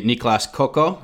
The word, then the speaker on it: Niklas Kokko: And you also selected one goalie, Niklas Kokko. I hope I mm-hmm. said that right. Yep.